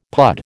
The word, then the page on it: pud: American English